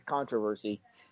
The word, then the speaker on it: controversy: I just like saying the British pronunciation of that. The English pronunciation is controversy.